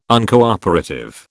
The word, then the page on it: Uncooperative: British English